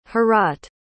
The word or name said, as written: Heorot